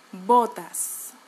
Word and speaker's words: botas: if you want to pronounce botas without an accent, you have to avoid avoid um, pronouncing the English O. Okay, O versus O, O, O. Botas.